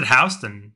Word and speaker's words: Houston: In New York, they pronounce it Houston because that's what Houston. – Yeah, because that's what SoHo stands for, south of Houston Street.